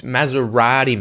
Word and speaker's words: Maserati: No, it's Maserati, mate. Maserati. – Maserati. No, is my T? – Maserati.